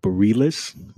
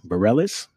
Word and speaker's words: Bareilles: Hey, listen, I recently listened to a song, a love song from Sarah, I believe you pronounce the last name, Bareilles, Bareilles, Sarah Bareilles, love song I mean, what an exceptional song.